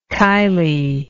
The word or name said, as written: kylie